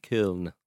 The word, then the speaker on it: Köln: Andy Brassel talked me into FC Köln.